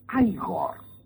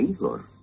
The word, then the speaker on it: Igor: No, se pronuncia Igor. A mí me dijeron que era Igor.